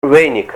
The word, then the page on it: weynik: Ninilchik Russian